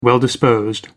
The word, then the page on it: well disposed: American English